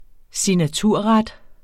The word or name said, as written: signaturret